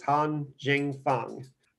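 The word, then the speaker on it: Hao Jingfang: I just did an interview with the Chinese translators, and it is Han Jingfeng.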